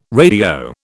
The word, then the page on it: radio: British English